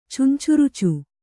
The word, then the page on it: ಚುಂಚುರುಚು: Kannada